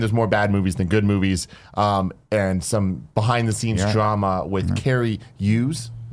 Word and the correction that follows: Cary Elwes: Is that how you say his name? Made the franchise worse. – Cary Elwes.